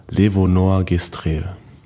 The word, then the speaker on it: Levonorgestrel: Also die Rede ist hier von LNG, das ist Levonorgestrel oder so, wie wird das ausgesprochen? – Levonorgestrel. – Genau, das auf jeden Fall.